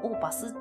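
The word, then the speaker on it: opus: で、この OP って何かって言ったら、オーパスって読みまして、作品番号のことなんですね。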